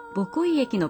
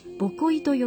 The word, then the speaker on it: 母恋: 遠く北海道のボコイ駅の売店では、母恋しいと書いてボコイと呼ぶ、この駅でもぜひとも拉致問題の解決の手助けをしたいと CD の販売が行われました。